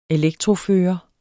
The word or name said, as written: elektrofører